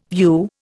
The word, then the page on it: view: Indian